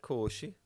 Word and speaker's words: Khashoggi: Khashoggi.